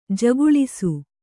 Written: ಝಕ್ಕುಳಿಸು

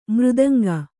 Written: ಮೃದಂಗ

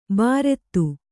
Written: ಬಾರೆತ್ತು

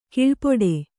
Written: ಕೀಳ್ಪಡು

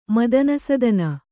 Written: ಮದನಸದನ

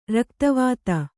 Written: ರಕ್ತವಾತ

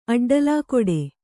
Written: ಅಡ್ಡಲಾಕೊಡೆ

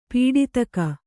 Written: ಪಡಿದೆಗೆ

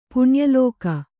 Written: ಪುಣ್ಯಲೋಕ

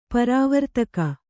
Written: ಪ್ರವರ್ತಕ

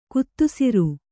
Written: ಗೋಧಿಸರ

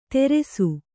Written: ತೆರೆಸು

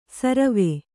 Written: ಸುರ್ವು

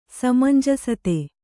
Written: ಸಮಂಜಸತೆ

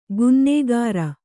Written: ಕನ್ನಾಗರು

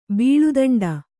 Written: ಬಾಳೆದಿಂಡು

